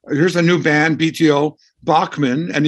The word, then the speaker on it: Bachman: All right, so um, uh, but the Bachman part, okay, I, I, promised we'd come back to that because, as I said, I grew up calling you Randy Bachman, um, even though it's spelled CH.